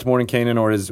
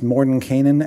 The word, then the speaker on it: Mordenkainen: Anything else about Mordenkainen or his friends or apprentices before we sign off here? – His name has been pronounced Mordenkainen and Mordenkainen. As far as I know, both are correct.